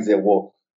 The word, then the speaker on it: Zewo: So I believe the correct pronunciation would be something like Zwo. It's zero in a modified French. So it would be something like Zwo.